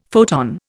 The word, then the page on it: photon: Indian